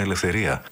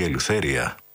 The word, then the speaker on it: Ελευθερία: Προς το τέλος του δίσκου, ένα τραγούδι για την ελευθερία, με την ελληνική λέξη, κάπως τραπατσαρισμένη, με αγγλική ή μάλλον αμερικανική προφορά, Ελευθερία ή Ελευθέρια,